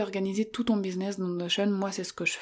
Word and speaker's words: Notion: Alors, L'outil Notion, alors ça s'écrit Notion, N-O-T-I-O-N, bon je le dis à l'anglaise quoi, mais l'outil Notion, euh, c'est un outil qui peut parfois être compliqué à prendre en main dès le départ.